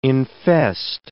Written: infest

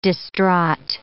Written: distraught

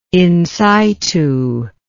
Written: insitu

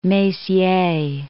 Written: Messier

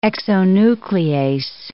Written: exonuclease

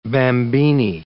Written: bambini

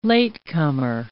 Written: latecomer

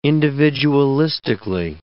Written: individualistically

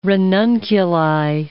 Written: ranunculi

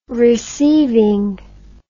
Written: receiving

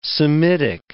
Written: Semitic

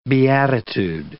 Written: beatitude

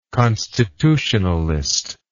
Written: constitutionalist

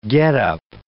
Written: getup

